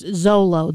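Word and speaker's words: Xolo: Yeah, but apparently it's pronounced Zolo.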